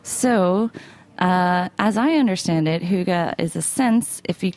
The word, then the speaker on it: Hygge: And the subtitle is Embracing the Art of Hygge. Am I pronouncing that right, first of all? I'm really trying. – Almost. You're almost there. – Okay. Hygge. Hygge. Hygge. Oh, okay. One more syllable.